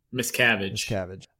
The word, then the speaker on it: Miscavige: Miscavige.